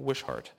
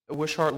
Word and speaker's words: Wishart: I think that's how you say his name, Wishart.